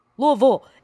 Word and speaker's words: Louisville: Louisville. It's two syllables.